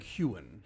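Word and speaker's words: Heughan: He's a Scottish actor. The G is not pronounced. It's like Huygen.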